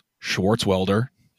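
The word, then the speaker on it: Swartzwelder: First of all, one word, three syllables, Schwartzwelder.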